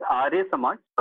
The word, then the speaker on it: Arya Samaj: So from what I understand, you belong to the Ayar Samaj, if I pronounce that correctly. And you were raised in this religion did I get the pronunciation? The Arya Samaj? – Yes, it's Arya Samaj.